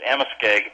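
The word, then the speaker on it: Amoskeag: Amaskegue Presbyterian Church, and if you could correct me if I've mispronounced that. – Yeah, you came as close as anyone ever has without hearing it first. It's Amaskegue. It's an Indian name, and it comes Indians there in that part of New England.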